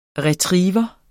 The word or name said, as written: Retriever